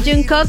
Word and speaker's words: Jungkook: Jungkook.